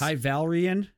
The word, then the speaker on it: High Valyrian: High Valyrian? – Yes. – Is that how you pronounce it? – Ah. – Yeah, that's the Game of Thrones.